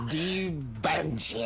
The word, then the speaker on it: D'Banj: It's D-B-A-N-J, but the D as an apostrophe behind. I'm old school, he's 20-something. – No, you're pronouncing it well. It's perfect. Debange, I like it.